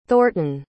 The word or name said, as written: thornton